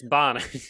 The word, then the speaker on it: Bonin: Bonin? How do you say it? Is it Bonin? – Bonin. – I've heard people say it a bunch of different ways. Chase. – It's Bonin. Yeah.